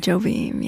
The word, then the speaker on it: Jobim: Jobim, yeah.